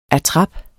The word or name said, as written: attrap